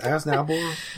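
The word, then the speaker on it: Aznable: Like, oh, we're used to how to pronounce it, Aznabel.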